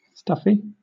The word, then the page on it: stuffy: British English